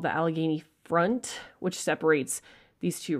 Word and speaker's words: Allegheny: Allegheny.